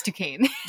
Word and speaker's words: Duquesne: It's Duquesne. – Duquesne, yes.